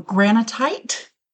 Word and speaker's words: granitite: I'm saying it wrong. Granitite. – Oh, okay. – It's like granite, but with an extra T.